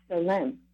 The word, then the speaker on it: tselem: And Salem is actually spelled T-S-E-L-E-M, but it's pronounced Salem.